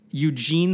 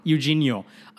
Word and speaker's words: Eugenio: Eugene Scalfari. – Eugenio.